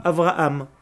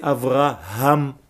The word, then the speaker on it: Avraham: Et donc, c'est faire attention, surtout quand on vient de France ou d'ailleurs, de prononcer la lettre He, qui est difficile pour les francophones, qui prononcent généralement le He comme un Aleph, Avraham. Ce n'est pas Avraham, c'est Avraham.